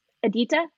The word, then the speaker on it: Edita: How do you say her name? – Editha, E-D-I-T-A.